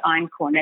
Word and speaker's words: einkorn: So it's called einkorn, and it's spelled E-I-N-K-O-R-N.